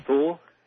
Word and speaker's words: Thule: The Who Society? – Thule. – How do you spell that? – I want to say, T-H-U-L-E.